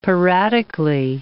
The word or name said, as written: piratically